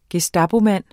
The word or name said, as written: gestapomand